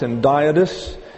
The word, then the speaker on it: hendiadys: That's pronounced hendiadys, defined by the Oxford Dictionary of Literary Terms as follows.